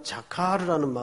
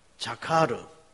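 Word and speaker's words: 자카르 (זכר): 여러분, 오늘 어쩌면 이 기억했다라는 자카르라는 말은 성경 8장 전체에 걸친 중심 언어가 될 겁니다. 그래서 히브리음으로도 한번 여러분 되뇌어 보는 것이 신앙에 유익이 될것 같아서 한번 같이 따라서 해보십시다. 자카르.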